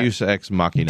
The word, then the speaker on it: Deus Ex Machina: Deus Ex Machina. – Deus Ex Machina, whatever, however you pronounce it.